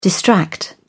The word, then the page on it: distract: British English